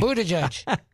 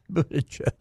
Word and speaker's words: Buttigieg: Well, the South Bend mayor, however you pronounce his name. – Buttigieg. – Buttigieg.